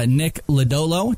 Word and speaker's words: Lodolo: You've got Nick Lodolo. Did I say it correctly, Jim? – You did.